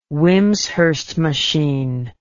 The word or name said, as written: Wimshurst machine